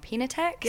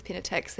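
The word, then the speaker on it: Piñatex: I'm going to say it wrong, Pinatex? – Yeah, perfect.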